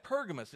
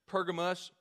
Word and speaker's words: Pergamos: You're sitting in the pew, you're sitting in the seat, and you're saying, man, I've got Pergamos. Is he talking about a different? No, it's the same one. Same church, same area, just a different pronunciation of it. No matter Pergamos, Pergamum, they were rewarded, they were praised for this action.